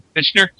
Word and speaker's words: Fichtner: How do you say his name? – Bill Fichtner, I think. – Fichtner.